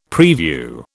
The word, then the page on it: preview: British English